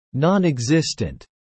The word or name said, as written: nonexistent